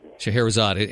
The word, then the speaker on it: Scheherazade: The first song, how do you say that, Scheherazade? – Scheherazade.